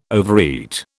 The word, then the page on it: overeat: British English